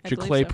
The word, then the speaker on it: Giclée: Giclée print.